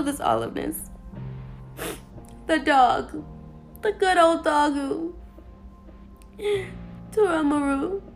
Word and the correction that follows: Toromaru: Ew, I said the name wrong. Pause, rewind. I will not disgrace his name like that. The good old doggo, Toramaru. I said the name wrong. I'm upsetting myself, guys. Pause, rewind, redo. Toromaru, there you go.